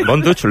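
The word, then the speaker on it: Von dutch: Así que, Bonduch, ¿lo he pronunciado bien? – Sí, muy bien.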